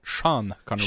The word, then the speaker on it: Sean Connery: Bon, je pense que j'ai mal prononcé son nom. Yann Conné ou quelque chose comme ça, non? – Moi, j'ai entendu Sean Connery.